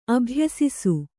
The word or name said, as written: ಅಭ್ಯಾಸಿಸು